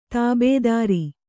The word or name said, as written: ತಾಬೆದಾರಿ